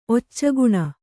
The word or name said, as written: ಒಚ್ಚಗುಣ